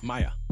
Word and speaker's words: Mya: If you saw a name spelled M-Y-A, how would you pronounce that? – Maya.